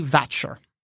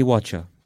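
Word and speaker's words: Wachter: The name written beneath this is Lady Vatcher, with a W. Vatcher, but with a W. – Lady Vatcher, but spelt Watcher? – Yeah, spelt Watcher, but with a, it would be pronounced Vatcher.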